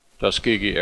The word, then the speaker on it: Tuskegee: A murmur went through the crowd after he started by mispronouncing the name of the group of men he was honoring. – The Tuskegee Airmen are a living example of the most basic American values. – Boehner was followed by Senate Republican leader Mitch McConnell of Kentucky. He was interrupted by applause after pronouncing the group's name correctly.